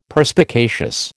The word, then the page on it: Perspicacious: American English